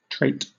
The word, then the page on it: trait: British English